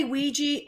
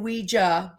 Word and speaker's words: Ouija: Okay, so people say Ouija and Ouija. Both ways you can say it.